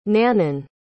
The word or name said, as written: nanon